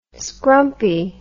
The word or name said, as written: scrumpy